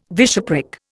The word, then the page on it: bishopric: Indian